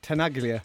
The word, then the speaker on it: Tenaglia: Danny Tenaglia.